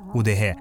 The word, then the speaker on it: удэге: Есть версия, что удегейцы, или если правильно произносить удеге, переводится как «люди леса».